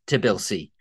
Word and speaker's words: Tbilisi: I just pronounced it a bit wrong. There's some random B in there. What are you talking about? I think that's a great guess, Tbilisi.